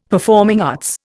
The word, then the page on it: performing arts: Indian